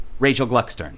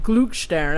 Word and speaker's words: Gluckstern: How many times in your life would you average that you have used the phrase, it's pronounced just the way it's spelled? – I lost track. However, then people try and be clever and do the German pronunciation, you know, like Gluckstern, and I'm sorry, I'm American.